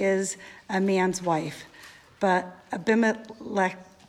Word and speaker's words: Abimelech: And Abimelech, king of Gerar, sent and took Sarah. But God came to anyone want to pronounce that for me? – Abimelech.